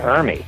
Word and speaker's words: Ermey: But am I saying that right? Yeah, Ermey.